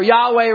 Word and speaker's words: Yahweh: It should have a Y sound, thus the word Jehovah. Okay? So which one we're going to use, Jehovah, or Yahweh rather.